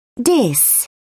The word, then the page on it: diss: British English